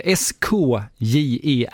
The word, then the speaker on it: Skjern: Är det korrekt uttal på den här danska klubben? – Jag duckar. – Ja, SKJERN, Skjern.